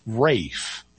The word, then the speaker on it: Ralph: I'm not sure how he pronounced his first name either, because the name Ralph, R-A-L-P-H, oh, I know this one. – Is sometimes pronounced Rafe. – Yeah. – That's like the L drops out.